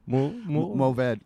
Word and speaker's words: Mourvèdre: I guess goodbye, Mourvèdre. – How do you say? – Mourvèdre. – Oh, good job.